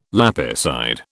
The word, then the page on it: lapicide: British English